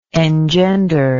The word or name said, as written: engenderment